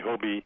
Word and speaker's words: HOBY: By the way, Hobie, it's pronounced Hobie, and it's H-O-B-Y, it's short for my name, Hugh O'Brien Youth.